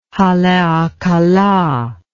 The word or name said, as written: haleakala